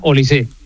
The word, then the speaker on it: Olise: Lleva acento, pero se pronuncia Olise.